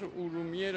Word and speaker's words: ارومیه: به عنوان مثال، مثلا یه جغرافیدانی در قرن پنجم میزیسته به نام استخری، این کتابی داره به نام مسالک و ممالک، در اونجا شهر ارومیه رو که خودش دیده به صورت الف ر و میه، یعنی ارومیه به این صورت تلفظ میشه.